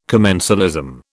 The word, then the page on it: commensalism: British English